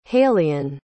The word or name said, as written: halian